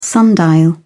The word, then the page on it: sundial: British English